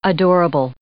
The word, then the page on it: adorable: adjective